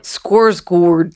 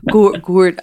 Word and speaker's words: Skarsgård: Also, I've been aggressively corrected by Swedes on the pronunciation of the Skarsgård brother family. – Okay. – It's like Skorsgård. Skorsgård.